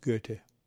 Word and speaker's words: Goethe: For those of you who don't know the spelling, it is G-O-E-T-H-E, pronounced Goethe.